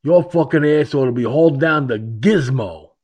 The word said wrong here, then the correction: Gitmo: Now, of course, it's actually pronounced Gitmo, but you get the idea, folks.